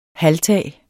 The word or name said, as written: halvtag